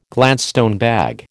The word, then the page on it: Gladstone bag: American English